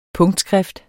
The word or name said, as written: PUNKTSKRIFT